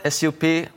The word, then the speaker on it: SUP: Also the soup board, SUP. Is that how you pronounce it? – Sup. But like stand-up paddle board for...